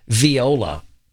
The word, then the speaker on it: viola: And then we got a text from Tulsa Todd that says, I played the viola for 4 years. It's pronounced viola. Sorry. – Well, if you're from the South, it's viola.